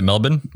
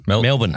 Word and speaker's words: Melbourne: And I reckon you almost said that properly as well. Well done. – Is it Melbourne? – Melbourne. – How do you Melbourne. – Say it? Melbourne. – Not Melbourne, as most Americans say.